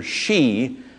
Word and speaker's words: Hsi: His name is Pastor Shi, and it's spelt H-S-I.